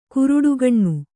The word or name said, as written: ಗರುಡಗಣ್ಣು